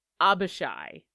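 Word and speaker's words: Abishai: Just so you know, I might have mispronounced this. It's Abashi is what it actually is.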